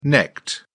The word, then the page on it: necked: British English